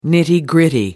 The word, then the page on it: nitty gritty: American English